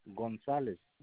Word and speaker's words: González: Ramiro González. – Can you spell it? – Ramiro, Ramiro González, GONZALEZ.